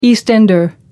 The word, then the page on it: east ender: American English